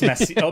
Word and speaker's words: Messier: It's named after Charles Messier. Messier is probably how you're supposed to pronounce it, because it's French, like mayonnaise.